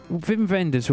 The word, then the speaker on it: Wim Wenders: While you're there, Wim Wenders, right, his name begins with a W, but that is official, that's how you say it.